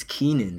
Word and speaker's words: Kennan: Like, it, my first name is spelled K-E-N-N-A-N. Mm-hmm. Uh-huh. So whenever people see it, they're like, Kenan. And I'm like, no, it's Keenan.